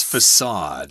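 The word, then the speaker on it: façade: And it's got that weird letter C there with a little diacritical mark at the bottom there. That's why the C is pronounced as an S, facade.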